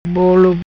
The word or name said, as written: Bolo